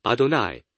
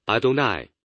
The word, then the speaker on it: Adonai: 然后我们的主是小写的，第一个读作 Adonai，这个读作 Adonai，它的意思是主人，我们的主人。